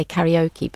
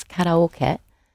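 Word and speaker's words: karaoke (カラオケ): People often know karaoke, pronounced differently now in English. People say karaoke, but it's karaoke, which is what? – A song in a pub.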